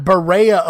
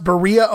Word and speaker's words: Berea: Not Berea, Ohio, as you might hear on TV, on the ID channel, things like that. People wanting to sound uppity and make it sound fancy. No, it's pronounced Berea, Ohio, and it's right around the corner from me, actually.